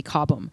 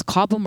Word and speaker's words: Cobham: So, I've been I don't know if it's Cobham or Cobham. I hope I don't get in trouble for not knowing how to pronounce it. – I think it. – You're fine. I think it's okay. Like everyone says both, so you're fine.